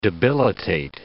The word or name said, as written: debilitate